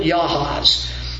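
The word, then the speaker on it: Jahaz: And Sihon, with all his people, came out to meet us in battle at Yahaz. You'll notice I do that from time to time. I know it's a J. We use J's. In the Hebrew language, they pronounce it like a Y.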